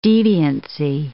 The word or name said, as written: deviancy